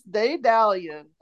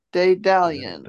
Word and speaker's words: Daedalian: My last word is Daedalion. And it's spelled D-A-E-D-A-L-I-A-N. Daedalion.